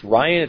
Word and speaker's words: Rian: I believe his name is pronounced Ryan. It's like spelled R-I-A-N.